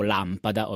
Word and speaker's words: lampada: Quindi se io dico lampada, ovviamente l'accento è posto sulla prima A. Abbiamo una parola sdrucciola, quindi lampada e non lampada.